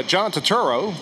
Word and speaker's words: Turturro: It's John Turturro.